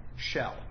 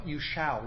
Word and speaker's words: shall: Leah Rose, you don't do this as much as your sister Kara does, but I pronounce shall, shell, like it's an E.